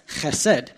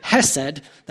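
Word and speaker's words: chesed (חסד): You have to get the ch in there to pronounce it properly, kind of like if you scare a cat, it'll go ch. Right? That is how you pronounce chesed. If you say hesed, that'd be a different word.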